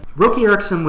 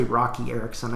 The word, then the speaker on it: Roky Erickson: And I think it's actually Roki Erickson. I just, when I see it spelled, I mispronounce it.